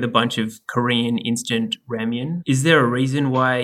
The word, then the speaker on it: ramyun: We couldn't do the Korean episode without the coup de grace of Korean instant nudes, and that's the kimchi ramyun. That's a ramen with a Y in it, so you have to pronounce it right.